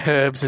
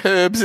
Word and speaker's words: herbs: And he was pronouncing herbs so fucking Britishly that I was like, you can't say herbs at a Subway. That's sad. That's like, they don't use herbs at Subway. They use herbs.